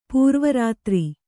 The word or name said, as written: ಪೂರ್ವರಾತ್ರಿ